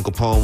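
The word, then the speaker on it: Kapone: So I am Tone Capone.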